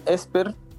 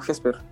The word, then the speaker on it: Hesper: Bueno, algunos le dicen Esper, pero nosotros lo pronunciamos al menos como Jesper. Em, puede ser de las dos formas en realidad.